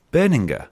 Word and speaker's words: Berninger: Berninger. – Yeah. It's a hard G.